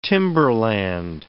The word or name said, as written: timberland